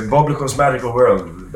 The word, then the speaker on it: Bobilikov's Magical World: Out of time, but Bobilikov's Magical World, that's how we pronounce it.